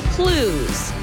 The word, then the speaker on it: Clowes: All you have to do is text the word CLUES. Like Clues Hall. It's spelled CLOWES.